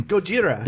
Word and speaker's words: Gojira: Or how do you say Godzilla? – Godzilla. Godzilla. – No, you Gojira. Say it all the time. – Gojira.